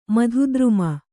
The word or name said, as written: ಮಧುದ್ರುಮ